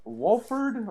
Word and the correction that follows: Wofford: or am I saying that right? – Wofford. Yeah.